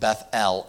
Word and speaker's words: Bethel: And here is what Bethel, we always just say Bethel, you know, but it literally is pronounced Bethel. L-E-L is actually the name of God.